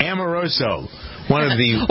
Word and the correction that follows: Omarosa: Or Omarosa.